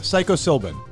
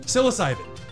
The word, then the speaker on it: psilocybin: Now, for those who don't know, myself included, obviously, the molecule that produces the psychoactive effect is called psilocybin, I think is how you pronounce it. – Psilocybin. – Psilocybin. You idiot.